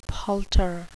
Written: palter

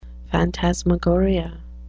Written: phantasmagoria

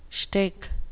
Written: shtick

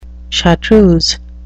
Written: chartreuse

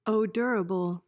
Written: odorable

